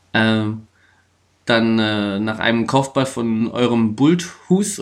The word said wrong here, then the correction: Bulthuis: oder Bulthus, wie das ausgesprochen wird. Bulthuis.